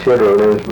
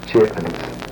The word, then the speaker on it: chitterlings: I would call them chitterlings because they've had several farm programs on this in which they describe the correct pronunciation of them and so forth. And it's a proper term to use chitterlings. – But regular usage is chitlins.